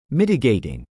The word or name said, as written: mitigating